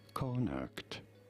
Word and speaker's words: Connacht: Connacht.